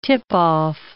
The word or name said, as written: tip-off